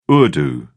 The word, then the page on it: urdu: British English